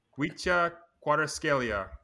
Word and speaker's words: Kvicha Kvaratskhelia: Kvicha Kvaratskhelia. I watched a YouTube video on how to pronounce it, but Kvicha Kvaratskhelia.